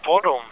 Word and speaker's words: Boddum: Det er en by, der hedder Bodum. Sådan tror jeg, det udtales. B-O-D-D-U-M. Bodum.